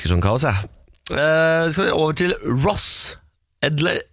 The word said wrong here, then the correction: Edgley: eh, Edgley? Ross Edgley, Edgley. – tror jeg man uttaler det som.